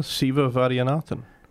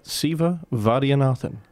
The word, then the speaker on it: Siva Vaidyanathan: And remember a long time ago, Siva Vaidyanathan. – Yes, and you've pronounced that better than I ever could. Try that again. – Siva Vaidyanathan.